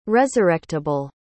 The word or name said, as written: resurrectible